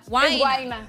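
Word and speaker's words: Guaynaa: El sencillo, pues participa ni más ni menos que con el puertorriqueño Guina, así se pronuncia. Guaina. Guaina. Sí.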